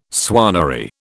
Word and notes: swannery: British English

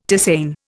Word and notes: tisane: Indian

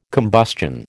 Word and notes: combustion: American English

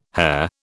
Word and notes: hare: British English

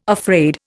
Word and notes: afraid: Indian

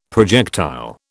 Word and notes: projectile: British English

